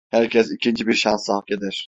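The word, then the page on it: eder: Turkish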